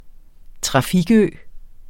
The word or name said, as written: trafikø